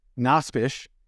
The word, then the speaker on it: Nosbisch: As I talked to one of my best friends and partners, Aaron Nosbisch.